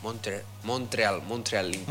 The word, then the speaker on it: Montreal Impact: Montreal Impact.